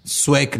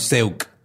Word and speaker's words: Szewc: Siempre tengo, es, que lo, lo, que me saca de pedo es de que se escribe S-Z-E-W-C, pero se pronuncia Suec, no Seuk.